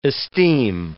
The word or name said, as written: esteem